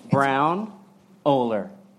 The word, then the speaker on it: Braunohler: Braunohler. – That's how you say it. – KB. Braunohler. Kurt Braunohler. – It's an awkward hug. Brown Oler.